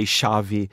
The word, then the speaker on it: Xavi: problems pronouncing his name because it's X-A-V-I. Mm. In Portuguese, would say Xavi. In English, I think you say Xavi or Xavi, something. I'm actually not sure.